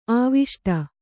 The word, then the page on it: ಆವಿಷ್ಟ: āviṣṭa